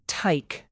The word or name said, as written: tyke